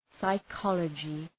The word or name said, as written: psychology